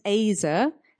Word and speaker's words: Ezer: So just looking at the word helper, the Hebrew word is Ezer, E-Z-E-R, pronounced Azer, and it's a combination of two roots, meaning to rescue and to save, and strength and power.